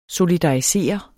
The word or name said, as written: solidarisere